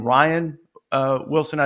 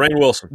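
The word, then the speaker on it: Rainn Wilson: And the reason was the guy who played Dwight Schrute, do you pronounce his name Ryan Wilson? I don't know. It's spelled like Rain. – It's spelled differently. – Rain Wilson. – Rain. Yep. Is it Rain Wilson?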